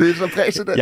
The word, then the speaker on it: præcedens: Det er jo det, man snakker om, at det danner præcedens, ikke? – Præcedens, tror jeg, det udtales. – Nej, det er præcedens.